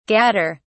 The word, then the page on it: gadder: British English